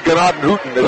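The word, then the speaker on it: Gnadenhutten: Yeah, it's actually Ganaden Hutton, if you want to say it correctly.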